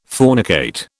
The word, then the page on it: fornicate: British English